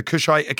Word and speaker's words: Kushite: The Kushite kingdoms? – Yep.